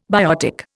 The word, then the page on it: biotic: Indian